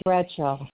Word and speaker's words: Bradshaw: You said Crenshaw? – No, Bradshaw. – Could you spell that for me? – It's Bradshaw.